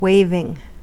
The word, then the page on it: waving: American English